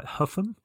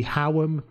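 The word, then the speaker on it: Hougham: I've got Dominic, I hope I'm pronouncing this right, Huffam. So it's H-O-U-G-H-A-M. – See, it's trouble. The English language say Huffam. It could be Howam, couldn't it? It could be Hewam.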